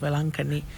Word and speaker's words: Velankanni: In the same way, in India, we have a popular shrine called Our Lady of Velankanni.